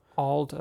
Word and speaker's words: Auld: Auld A-U-L-D. It's a but trip. It almost sounds like they're pronouncing it old, but it's old. And it's the same as all like Auld Lang Syne.